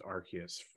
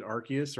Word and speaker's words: Arceus: Do you pronounce it Arceus or Arceus? Arceus. So it's like there's there's there's no there there is no definitive thing. I hear it pronounced both ways, and I myself will pronounce it both ways in you know the same the same instant. So yeah, Arceus, Arceus, whatever you wanna whatever you wanna say.